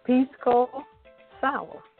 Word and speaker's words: Pisco Sour: It's called the Pisco Sour.